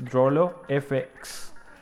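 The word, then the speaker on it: Drolo FX: Lo que vamos a escuchar es un video de YouTube que está dentro del canal de Droll FX, que lo ubican así con Drollo, se escribe DROLOFX, Drollo FX.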